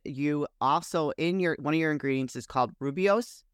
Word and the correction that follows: Rooibos: Did I say that correctly? – Rooibos.